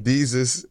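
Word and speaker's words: Desus: and he tried to tell me that Deuce and Morrow broke up, but it's really their podcast is going. Nail Cam. – Deuce. – Do you really? Deuce. How do you say it? – Deezus and Mero.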